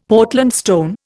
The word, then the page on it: Portland stone: Indian